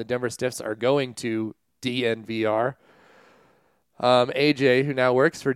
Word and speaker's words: DNVR: You would think it would be called Denver and they'd just say it like a tech company, but it's not. It's just DNVR.